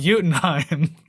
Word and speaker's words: Jotunheim: Although it's in, if you want to be American about it, it's Jotunheim, but it's pronounced Jotunheim.